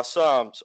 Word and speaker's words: Psalms: Where I'm reading this morning on the weekends, I read through Proverbs and Psalms or Palms. I don't know how to say the word right. I don't know if the P is silent or the S is silent, but either way.